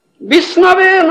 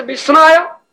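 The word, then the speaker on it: Vishnave (विष्णवे): The Pandit says, Vishnave nama. You understand? Murkha says Vishnaya, Pandit says Vishnave. You understand? Who was? Vishnaya, Vishnave. The correct word is Vishnave.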